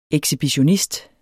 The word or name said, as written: ekshibitionist